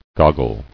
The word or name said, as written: Goggling